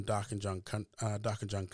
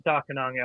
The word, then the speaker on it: Darkinjung: Darkinjung elder Tommy Sales used to say Darkinjung, D-A-R-K-I-N-O-O-N-G. But the Darkinjung Land Council currently spell it as Darkinjung, and some folks say it a bit differently. I tend to follow elder Tommy Sales' pronunciation for Darkinjung.